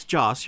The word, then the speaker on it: Joss: Can you name off some of the shows that Joss Whedon, I'm almost scared I'm saying his name wrong. – No, it's Joss, you're right.